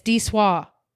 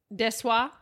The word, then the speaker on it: Desoy: It's Desoy. – Desoy. – Desoy. Desoy. – Oh, that makes sense. – So, yeah, that's how you say it. When you look at it, it's just D-S-O-I. And then in a real itty-bitty print, it says Desoy.